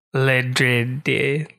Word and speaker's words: Ledrede: L-E-D-R-E-D-E. – Ledred? Ledred? Ledred? – It's not Le, they're Irish. – Ledred. Ledrede.